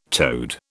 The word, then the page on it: toad: British English